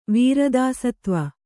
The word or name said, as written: ವೀರದಾಸತ್ವ